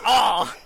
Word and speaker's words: our: O-U-R, it's pronounced R.